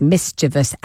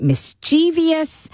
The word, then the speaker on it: mischievous: And Susie Dent has said that many, many people, a sizable percentage of the population, mispronounces mischievous as mischievous, and she doesn't mind it anymore, and we should simply accept the extra syllable.